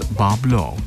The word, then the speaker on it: Bois Blanc: After a time, the pronunciation shifted to Bois Blanc Island, but still sometimes known as Bablo to local residents.